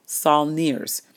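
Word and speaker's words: sauniers: And as the salt crystallizes, in many cases, it's hand harvested by people called salineers. And I'm going to spell that for you. S as in Sam, A-U, N as in Nancy, I-E-R-S.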